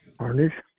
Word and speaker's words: Harnish: Harnish. – Would be able to spell that? – H-A-R-N-I-S-H.